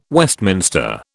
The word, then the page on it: Westminster: British English